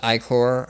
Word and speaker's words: Ichor: And he'd give you Ichor, and tells you that it's pronounced Ichor, and like he it, like gives you, it's like, it's I-C-H-O-R. And then, like, the fish is like, that's pronounced E-Y-E-C-O-R, by the way.